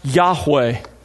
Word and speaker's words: Yahweh: a translation of God's personal name, pronounced Yahweh.